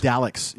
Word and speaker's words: Dalek: And you know, I think we both pronounce it wrong because I'm pretty sure Daleks. It's Daleks. – Dalek. Dalek. Oh. – I just watched the newest like Christopher Eccleston episode, and it's Dalek.